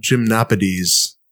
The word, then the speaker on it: Gymnopédies: What about Gymnopédies? – that was right.